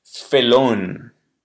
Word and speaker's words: felón: Siguiente, felón. Felón con acento en la O.